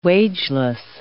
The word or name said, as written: wageless